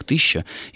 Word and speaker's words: тысяча: С другой стороны, меня удивило, я только вот недавно узнал, что слово «тысяча» произносилось как «тыща» еще в средние века на Руси. То есть вот эта вот особенность была характерна очень-очень давно. И тогда это было нормой? – Нет, это просто так же был вариантом произношения.